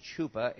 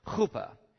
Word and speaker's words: chuppah: The word, it looks like it'd be pronounced chupa in the Hebrew, is ha sound, so it's chupa.